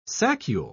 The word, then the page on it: saccule: British English